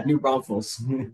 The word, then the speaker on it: New Braunfels: Yeah, New Braunfels.